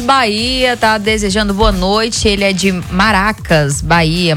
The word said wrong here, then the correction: Maracás: Maracas, Maracás, sabe como que pronuncia? Maracás, Bahia.